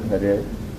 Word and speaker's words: Hare: The first is Hare. – Первое Hare. – You can repeat. – Можно повторить. – Hare. – Hare. – Two syllables. Ha and re.